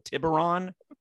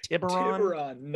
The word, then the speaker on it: Tiburon: I'll have to call the creatives or something like that and be like, can you pronounce the name of this model, Tiburon? Am I saying it right? Tiburon.